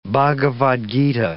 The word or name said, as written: Bhagavad Gita